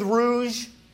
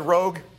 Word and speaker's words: rouge: You'll be fixing your hair, covering your spiritual blemishes with rouge. Did I say that right? Rouge? Good. I almost said rogue, but I know that's not it.